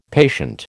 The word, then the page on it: patient: American English